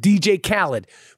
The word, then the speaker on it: Khaled: DJ Khaled's name is pronounced DJ Khaled, but there's no I in it.